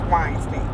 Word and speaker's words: Weinstein: It's Weinstein.